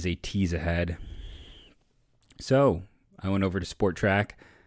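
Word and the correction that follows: Spotrac: I always say it wrong. It's not Sport Track, Spot Rack.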